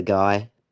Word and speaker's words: Guy: You could say what a guy, but the pun doesn't quite work as it's pronounced Guy.